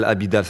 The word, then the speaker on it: Abidal: Il y avait, il y avait Abidal, déjà, il y avait Amidal, Abidal, ça, je me waouh, il fait trois syllabes.